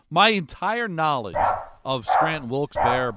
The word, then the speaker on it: Wilkes-Barre: What is the exact pronunciation? Because it's B-A-R-E, correct? – Let's ask our Pennsylvania native what he says. – So I always said Scranton Wilkes-Barre.